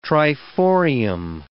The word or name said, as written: triforium